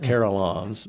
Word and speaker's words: carillons: And did you ever play the carillons? Or? – No, I never got to do that. – Am I pronouncing it right? – Yes, the carillons.